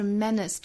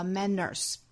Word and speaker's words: menace: 还有一个要点呢，就是 menace 这个词不要读成了 manners。单词里只要不含有字母 R 是不可能卷舌的，不管音还是美，所以这个词的音和美是相同发音的，都读 menace, menace, 第二个音节是 schwa sound, menace, success is a menace, success is a menace。